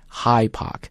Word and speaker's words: Hyde Park: 然后另外一个地方就是大家都去啊，就 High Park, 注意这个词读法不要读成 Hyde Park, 咱们中文翻叫海德公园，但是那个德不要读出来，它是一个失去爆破的音 High Park。